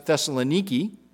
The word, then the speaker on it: Thessaloniki: They pronounce it Thessaloniki, but you can go and visit the modern city, and in the midst of that is the ruin of the ancient city.